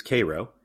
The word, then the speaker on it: Cairo: So you have Cairo, spelled like the Egyptian city, but definitely not pronounced like Cairo. It is Cairo.